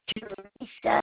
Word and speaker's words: Teresa: It's Teresa with no H.